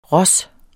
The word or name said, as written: ros